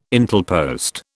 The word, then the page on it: Intelpost: British English